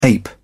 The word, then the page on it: ape: British English